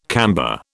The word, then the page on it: camber: British English